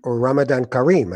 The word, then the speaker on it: Ramadan Kareem: Or Ramadan Kareem, I could say as well.